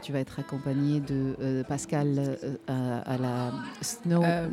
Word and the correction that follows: Snook: Snook. Ah je, prononce toujours. Snook.